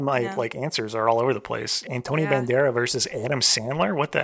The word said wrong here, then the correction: Banderas: Also realize there's an, we're saying it wrong. There's an S in his name. – Antonio Banderas. – Yeah. – Banderas. – There's an S at the end. S, not Z. Antonio Banderas.